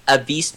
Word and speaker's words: Avice: Like, I'll, I'll pronounce my name slowly and I'll spell it out. So, first name is Anthony. My last name is Avice so it's A V I C E space D U space B U I S S O N.